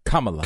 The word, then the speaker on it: Kamala: How do you say her name? – Kamala. – Kamala Harris.